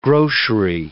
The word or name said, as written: grocery